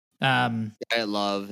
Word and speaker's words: Dyatlov: Dyatlov is how you say it.